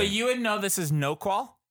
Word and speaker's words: Noqual: How do you guys pronounce 22. – This? – 22. – N-O-Q-U-A-L. Noqual? – Diaspora. – Noqual? – Oh, yeah. Yo, man, that's Noqual.